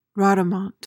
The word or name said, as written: rodomont